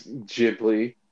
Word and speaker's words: Ghibli: Is it pronounced Studio Ghibli or Ghibli? – It's Ghibli.